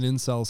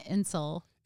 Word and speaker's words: incel: Incel, I-N-C-E-L. – Incel? Is that how you pronounce it? – Well, it's short for involuntary celibate. – So, that's what it's short for? – Yeah, so if you pronounce it like incel.